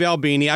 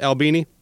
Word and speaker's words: Albini: Let me welcome to the program, Steve Albini. I got your name. That's the proper pronunciation for your last name, Albini? – Yep.